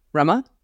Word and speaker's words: Rema: So let me just say my name is spelled R-E-M-A. but it's pronounced rum like Bacardi, Rama, also my drink of choice.